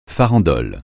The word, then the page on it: farandole: francais